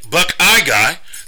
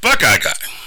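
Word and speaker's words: Buck-I-Guy: And Buckeye Guy sounds like Buckeye Guy, which it is, but it's spelled B-U-C-K, space I, space God.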